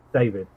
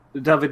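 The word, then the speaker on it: Dafydd: Is that David with two Fs, or are we dealing with the V? – David is, okay, the way you spell it is D-A-F-Y-double-D.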